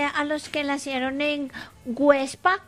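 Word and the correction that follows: Güepsa: Perdón, Huepsa. Websa. ¿Ah? Con diéresis.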